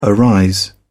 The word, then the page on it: arise: British English